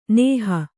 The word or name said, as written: ನೇಹಿ